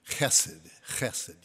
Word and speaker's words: chesed: it would be transliterated to C-H-E-S-E-D. Chesed. You got to get it back in the back of your throat a little bit.